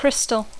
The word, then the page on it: crystal: English